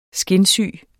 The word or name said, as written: skinsyge